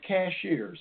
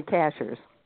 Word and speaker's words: Cashiers: Now, first of all, I am from the mountains of North Carolina, and we always pronounced it cashiers. Is that correct? – That's correct. Or no, well, actually, most people around here say cashers.